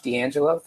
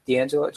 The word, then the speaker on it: D'Angelo: Again, my name is Josh D'Angelo. The last name is spelled with an apostrophe, and it does give a lot of challenges in life. But D'Angelo, Josh D'Angelo, you can find me on Facebook or Instagram, D-A-N-G-E-L-O.